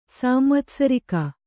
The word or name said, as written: ಸಾಂವತ್ಸರಿಕ